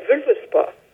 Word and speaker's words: Völuspá: This chapter is called Völuspá. You might want to correct my pronunciation. – Yeah, Völuspá. – Okay, Völuspá. And if you want to add anything about your biography and your work before we start.